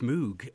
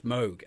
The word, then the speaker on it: Moog: When I was a kid growing up in Victorian England, it was Moog, Robert Moog and Keith Emerson playing his giant Moog. But it is, in fact, Moog, as opposed, rhyming with Vogue or Rogue.